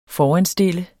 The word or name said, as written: foranstille